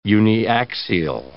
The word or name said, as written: uniaxial